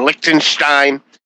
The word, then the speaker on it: Liechtenstein: There's Captain Canada, Captain Brazil, Captain Liechtenstein.